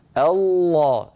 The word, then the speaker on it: الله: عندما تنطق لفظ الجلالة، وهذه فائدة أيها الإخوة استفيدوها جميعا، كل من يسمعني، من يسمعني، لما تقول الله، اسمعوني جيدا لو سمحتم، الصوت كله من الفم، ما في شيء من الأنف.